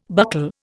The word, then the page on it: buccal: Indian